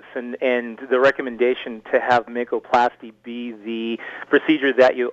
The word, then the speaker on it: Makoplasty: Now, the surgery that you had to replace your hip was called a makoplasty. Am I pronouncing that correctly? – That's correct, makoplasty.